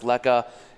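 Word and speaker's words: lekker: And by the way, everyone should know, Lekka, it's L-E-K-K-E-R, pronounced Lekka.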